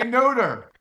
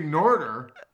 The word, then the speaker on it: Notre: No, that's how you say it. You're throwing an extra R in there before the T when you say Norder.